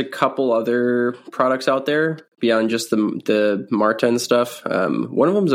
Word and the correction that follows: Morton: I think it's pronounced Morton instead of Martin.